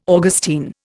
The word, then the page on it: Augustine: Indian